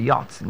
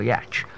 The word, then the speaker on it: yacht: So it's, I simply said as yacht, that's why it's pronounced the yacht, not a yatch.